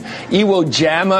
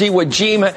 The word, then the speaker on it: Iwo Jima: She mispronounces words like you'd get wrong, like scourge and Iwo Jama or something she said the other night. It's like she's never said these words before. So somebody's writing words that she's never pronounced before. Somebody's putting this stuff in Bachman's face and saying, read this, and not even saying it's scourge, not scourge. It's Iwo Jima.